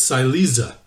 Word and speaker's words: Silesia: that district of Germany and Poland is referred to as Silesia. The reason I said Schlesia is because that's how it's pronounced in Hebrew, which I had assumed that that's just the way to pronounce it.